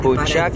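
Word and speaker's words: Bócsa: Bocsa qui s'écrit B-O-C-S-A, hein, le CS qui fait tché.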